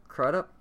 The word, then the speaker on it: Crudup: I think I'm saying his last name wrong. He's been in a lot of stuff. Crudup? – Crudup sounds right.